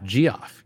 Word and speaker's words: Geoff: Geoff. Yeah, Geoff Schwartz. Yeah, he... – You realize that's just pronounced Jeff also.